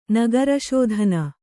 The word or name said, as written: ನಗರಶೋಧನೆ